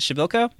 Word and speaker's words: Przybylko: Przybylko? – Yeah.